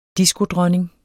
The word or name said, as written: diskodronning